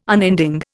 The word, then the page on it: unending: Indian